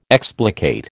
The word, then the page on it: explicate: American English